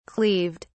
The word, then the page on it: cleaved: British English